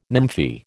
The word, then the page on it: nymphae: American English